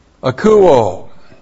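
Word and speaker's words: Akouo (ἀκούω): A-K-O-U long O. Akouo.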